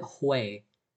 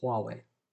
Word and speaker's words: Huawei: I used to pronounce it Hui. And then I started watching Lou Later and Unbox Therapy, and I was quite quickly corrected. Everybody calls it Huawei.